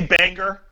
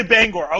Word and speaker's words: Bangor: She say Banger? – No, Bangor, Maine. She does say it right. She does say it right. – Oh, she does say Bangor.